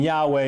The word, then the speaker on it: Yahweh: And then there's debates over what vowels are present in this Tetragrammaton, and so you either get Yahweh or Jehovah. Jehovah is most likely it's a happy accident of history that we're not going to get into, and Yahweh is most likely the correct pronunciation.